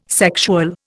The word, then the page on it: sexual: Indian